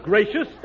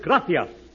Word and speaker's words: gracias: No, not gracious. That word is gracias. You see, in Spanish, the C is pronounced T-H.